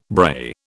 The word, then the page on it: brae: British English